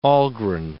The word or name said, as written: Algren